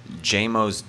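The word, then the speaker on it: Jaimoe: J-Mo's jazz band? Am I saying that right? Right, yeah, J-Mo.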